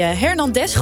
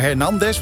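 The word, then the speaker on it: Hernández: Want ik zit ook met die klemtoon. Is het nou Hernandez? Want er zit een streepje op die A. Of is het Hernandez? – Ja, Hernandez.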